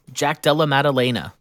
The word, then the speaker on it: Jack Della Maddalena: All right, next is Jack Della Maddalena, or am I saying that right? Maddalena? – Jack Della Maddalena.